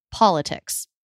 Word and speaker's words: politics: So an example of this would be the word politics. – Uh-huh. – Politics. Da-da-da. Stress is on the first syllable. But actually, the third syllable has secondary stress. That's why it's a true T.